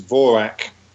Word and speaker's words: Morag: It's a Gaelic word. I mean, we would pronounce it Morag, and Morag is now a fairly familiar Scottish girl's name, actually. But the correct Gaelic pronunciation is Vorak.